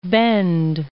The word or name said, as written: West Bend